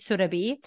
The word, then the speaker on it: Surabhi: Also, let's start with how my name is pronounced, is Surabi.